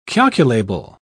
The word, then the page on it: calculable: British English